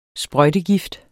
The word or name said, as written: sprøjtegift